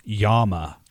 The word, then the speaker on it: llama: Well, here llama has two L's, and it's pronounced Yama.